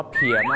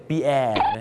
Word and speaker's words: pier: ่าคีย์ครับผมถูกต้องนะครับอย่างเช่นไปที่ออสเตรเลียที่ซิดนีย์ circular key นะฮะไปที่สิงคโปร์ครับ clark key นั่นเองครับคีย์นะครับก็จะเป็นท่าเรือนะครับที่ไม่ใหญ่มากนะครับเอาไว้รับส่งนะฮะผู้โดยสารกันมากกว่านะครับแต่ประเทศไทยนะครับถ้าจะเห็นนะตามป้ายเขามักจะเขียนคำว่าเพียมากกว่าครับไม่ใช่แปลว่าไม่มีแรงนะครับคนนั้นมันเพียครับผม P-I-E-R เพียแล้วออกเสียงว่าเพียนะครับบางคนนึกว่าปีแอร์นั่นมันชื่อคนฝรั่งเศสปีแอร์นะ